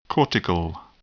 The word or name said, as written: cortical